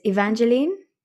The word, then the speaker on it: Evangeline: Just to double check I'm saying your name correctly, it's Evangeline? – Evangeline, yes, perfect.